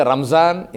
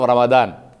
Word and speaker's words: Ramadan: We say Ramzan instead of Ramadan.